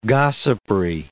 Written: gossipry

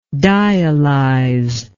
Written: dialyzation

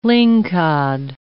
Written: lingcod

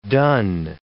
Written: dun

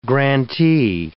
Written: grantee

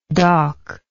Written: dak

